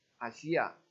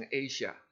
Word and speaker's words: 亚西亚: 从地图里面我们看一下，保罗在亚西亚，这个是亚西亚阿西亚啊，你不要念成 Asia，这个是希腊文的这个的发音是阿西亚，